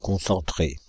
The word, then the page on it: concentrés: /kɔ̃.sɑ̃.tʁe/